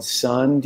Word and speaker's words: Sunn: Well, God, more than ten years ago, um, I was writing a piece about this band called Sun. Do you guys know Sun? – sun. – No, it's, spelled S-U, it's spelled S U N N with like an O, and like you know they're like this weird, out there kind of minimalist drone metal band from L.A.